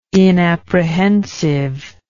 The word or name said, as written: inapprehensive